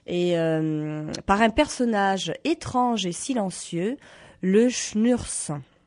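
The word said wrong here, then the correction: Schmürz: Le Schmurs, oui, c'est vraiment, voilà, c'est difficile à dire, ça s'écrit encore plus compliqué, mais bon.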